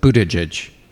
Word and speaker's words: Buttigieg: Yeah, just think boot edge edge, the word edge pronounced twice in a row with emphasis on boot. So Buttigieg.